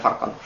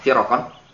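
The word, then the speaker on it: firq (فرقا): Setelah nampak jelas bagimu mazhab ahli sunnah, mazhab ahli sunnah, fa'lam, ketahuilah, anna firqan, bahwasanya kelompok-kelompok, firq itu bacanya firq ya, bukan farqan, firqan, bahwasanya kelompok-kelompok, zallat aqdamuhum fihi, kelompok-kelompok yang tergelincir kaki mereka pada masalah ini.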